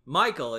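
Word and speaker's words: Mikeal: For those who don't know, my name, Michael, is spelt fucked up. It's M-I-K-E-A-L.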